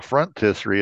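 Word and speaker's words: phrontistery: Our first word is frontisserie.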